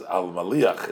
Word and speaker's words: Al Maliach: Uh, I said uh, his name was Matzliach. His name was Al Maliach. It rhymes with Matzliach, but not Matzliach.